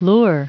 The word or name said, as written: lure